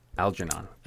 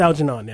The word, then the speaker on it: Algernon: I don't know how to pronounce that. – Algernon. – It's Algernon, yeah.